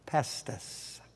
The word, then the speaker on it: pistis: The noun faith or belief translates this word, P-I-S-T-I-S, pestis. The cognate verb is spelled P-I-S-T-E-U-O, pestuo.